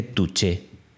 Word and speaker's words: touché: Creo que se dice touché, tal cual, touché.